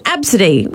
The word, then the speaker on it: Abcde: Someone said, Claire, she said, my son went to a friend's birthday party last year and the kid's name was Absidy, which, Absidy sounds all right, but it's spelt A-B-C-D-E.